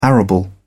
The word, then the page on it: arable: British English